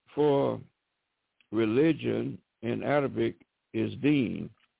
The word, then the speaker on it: Deen: So in the Holy Quran, Allah or God, Almighty God, describes the religion of Al-Islam as Deen Al-Fitra. It's pronounced as though it was spelled D-E-E-N, Deen. Al-Fitra, Al, A-L, and Fitra, F-I-T-R-A-H, which means the religion established on the laws and principles which uphold creation.